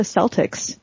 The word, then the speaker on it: Celtics: So our sports teams, we have a basketball team, which in America is pronounced the Celtics. That's spelled C-E-L-T-I-C-S, and it has a shamrock as the mascot, and their colors are green and white.